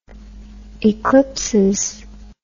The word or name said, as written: eclipsis